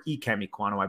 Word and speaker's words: Ikem Ekwonu: There is just a lot to love about Ikem Ekwonu, or Ikem Ekwonu, I believe is how it's pronounced.